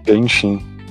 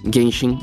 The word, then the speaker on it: Genshin: Genshin ou Genshin Impact. Pra... – Genshin. – Genshin, é.